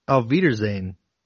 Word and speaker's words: Auf Wiedersehen: Oh, for Weiderschein. – Where is that at? – Scroll down a bit. 2:15 hours. – I think that's Auf Wiedersehen.